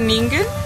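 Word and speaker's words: ningen (人間): Y la última, cuando estés nervioso, escribe un mano en japonés que se pronuncia ningen y haz como es que te lo tragas.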